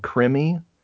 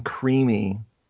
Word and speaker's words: Krimi: Those German ones, the crime films called Krimi. I was calling them Creamy. Creamy. And Brad's like, I don't think that's how you say it.